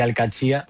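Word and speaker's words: Al-Qadsiah: Bueno, se suele decir Al-Qadsiyya. – Al-Qadsiyya. – Pero también hay gente que dice Al-Qadsiyya.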